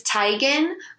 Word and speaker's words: Teigen: Chrissy Teigen, which she now says it's Teigen, but now she says it just sounds weird when people say it the correct way.